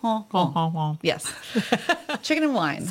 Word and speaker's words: coq au vin: Well, we had coq au vin.